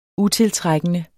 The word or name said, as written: utiltrækkende